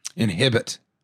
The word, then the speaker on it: inhibit: It says can inhabit both lions and mucus. – Mm-hmm. Inhibit. – Inhibit. – It's pronounced inhibit.